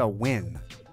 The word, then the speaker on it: Nguyen: Boom, shalak, boom, season two with Lisa Nguyen of Telehue Foods, right? – Yes.